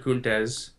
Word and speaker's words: Kuntz: Is that how you pronounce his last name? K-U-N-T-A-Z. – That's it. It's got to be it. No, it's Tom Kuntz. It's Kuntz and Mike McGuire.